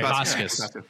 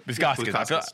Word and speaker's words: Bukauskas: am I saying his name right? – Yeah. – Okay. Bukauskas. – Bukauskas. I felt like I was missing a syllable.